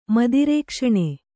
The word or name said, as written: ಮದಿರೇಕ್ಷಣೆ